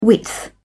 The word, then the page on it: width: British English